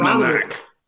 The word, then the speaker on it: Aronimink: Aronimink. – Aronimink.